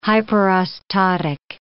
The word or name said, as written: hyperostotic